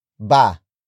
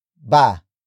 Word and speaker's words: ba: That particle is ba. Ba. Ba. Ba is spelled as B, A.